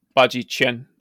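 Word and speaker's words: Bajiquan: It's the way that chi is pronounced. If it's qi, it's Bajichen.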